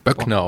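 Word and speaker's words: Boeckner: Er wird ersetzt vorher schon, also bevor er in die Band kam und auch jetzt wieder durch äh, Dan Buckner, glaube ich, spricht man es auch. – Böckner auf gut Deutsch. Ne? – Böckner.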